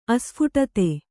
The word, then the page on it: ಆಸ್ಫೋಟಿತ: āsphōṭita